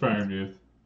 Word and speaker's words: Freiermuth: I think that I'm gonna probably say his name wrong, but Freiermuth. Freiermuth. – No, it's Friermuth. – Oh, I got it right.